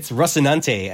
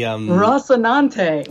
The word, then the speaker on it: Rocinante: It's Rocinante, and it's the... – Rocinante.